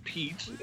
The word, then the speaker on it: Piet: Yeah, it's Pete. I mean, it's just like the U.S. name.